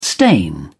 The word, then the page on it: stain: British English